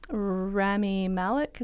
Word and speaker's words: Rami Malek: and now it's Rami Malek.